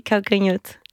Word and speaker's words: Calcanhoto: Calcanhoto. – Oui, Calcanhoto.